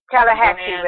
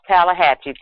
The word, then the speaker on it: Tallahatchie: What they said was they made the mother, the aunt, grand aunt, and the Tallahatchie River. – Tallahatchie, thank you. I knew it was something like that, and I just couldn't pronounce it properly.